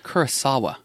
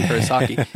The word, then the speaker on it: Kurosawa: Kurosawa, by the way. I'm not saying Kurosaki. It's Kurosawa.